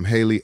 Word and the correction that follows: Halle: Hallie, is it Hallie or Haley? I want to say that right. – I think it's Hallie. – I think it's Hallie, right? – Yeah.